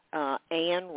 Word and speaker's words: Ann: So my website is my name, Anne Ranson.